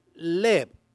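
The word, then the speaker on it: leb (לב): La palabra cordura viene, es leb, L-E-B, leb.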